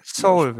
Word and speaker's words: Seoul: Seoul, würde ich sagen. Seoul. Es ist so wie die koreanische Hauptstadt.